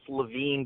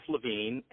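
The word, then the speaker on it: Levin: So I think the one that we're actually using is Jeff, and my name is pronounced Levine, but without an E.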